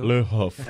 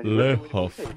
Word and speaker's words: Le Havre: Pronúncia, Le Havre. Le Havre. – Bacana. – Gostou? E tem um biquinho. Lucas não viu, não, mas tem. Le Havre.